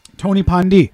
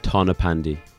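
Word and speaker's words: Tonypandy: Tony Pandi. Is that how you say it? – No. – What is it? – Tana Pandy.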